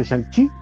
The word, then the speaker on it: Shang-Chi: Yo solo sé que si Kevin Feige lo pronuncia Shang-Chi, se llama Shang-Chi.